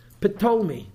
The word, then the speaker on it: Ptolemy: And in English, you know how Talmai is spelled? With a silent P. Ptolemy. There's actually a brand of olive oil called Ptolemy. Why they chose that name, I don't know. But Talmai was a generic name for all the Egyptian kings, and the P is silent, okay? They ruled Israel for about 200 years.